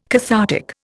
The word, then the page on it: cathartic: Indian